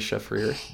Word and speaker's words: Shafrir: It's called Startup by the great Doree Shafrir.